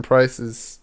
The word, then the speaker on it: Pryce: for Jonathan Pryce's... Did I say it again? I said it right this time. Yeah, the price is right in this case.